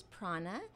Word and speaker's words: Praana: Their name is Prana. Prana like chi. Life force energy, but it's spelled P-R-A-A-N-A.